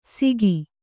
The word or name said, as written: ಸಿಗಿ